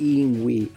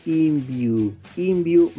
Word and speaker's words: Yngwie: No es Yngwie, así mismo lo dice él, pronuncien bien mi nombre, es Yngwie Malmsteen.